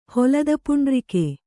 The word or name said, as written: ಹೊಲದಪುಂಡ್ರಿಕೆ